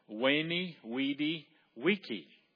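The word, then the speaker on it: veni, vidi, vici: you're supposed to pronounce it with a W, Weenie, Weedy, Wiki.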